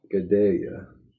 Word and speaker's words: Gadelha: Gadelha.